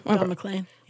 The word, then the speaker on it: Don McLean: Don McLean.